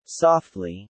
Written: softly

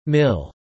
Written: mill

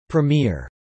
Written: premier

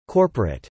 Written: corporate

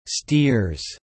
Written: steers

